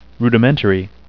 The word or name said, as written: rudimentary